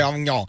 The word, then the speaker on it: Avignon: I'm going to say Avignon.